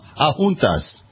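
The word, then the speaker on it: Adjuntas: I have a Puerto Rican on my staff, so he wants me to say Ajuntas. That's the town. Ajuntas. Ajuntas. He said I said it right.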